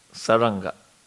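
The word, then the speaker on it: Śārṅga: How do you pronounce it? Sarganda. Two syllables. – Yeah. – But the N gets kind of... – Yeah, R and the N, there is a syllable between that, Saranga. – Saranga. Thank you. – Generally pronounced Saranga.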